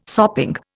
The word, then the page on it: sopping: Indian